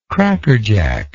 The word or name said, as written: crackerjack